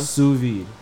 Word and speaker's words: sous vide: Sous vide.